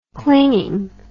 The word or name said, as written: clinging